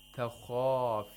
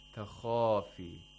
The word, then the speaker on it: تَخَافِ: If you say takhafi, takhafi, that's as if you're pronouncing the kha with a fatha.